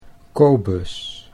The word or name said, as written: Kobus